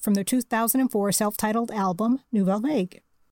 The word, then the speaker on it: Nouvelle Vague: Nouvelle Vague, was also cooking up a cover of Love Will Tear Us Apart, and a cover of Blue Monday.